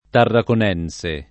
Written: tarraconense

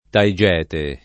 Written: Taigete

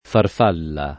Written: farfalla

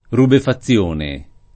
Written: rubefazione

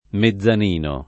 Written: mezzanino

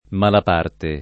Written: Malaparte

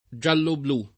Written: giallo-blu

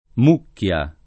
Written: Mucchia

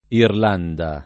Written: Irlanda

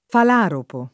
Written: falaropo

